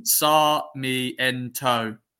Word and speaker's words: Sarmiento: Sarmiento. Is that how you say it? – That's exactly how you say it.